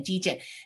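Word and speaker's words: Dejen: And back then I had a license plate that said D-Gen, because that's what they would call me, like Jennifer. And I spell it D-E-J-E-N, D-Gen.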